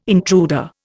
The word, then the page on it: intruder: Indian